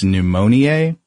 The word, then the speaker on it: pneumoniae: But anyway, Griffith started with two different strains of bacteria, which was Streptococcus pneumoniae. Is that how you pronounce that? – That sounds good to me.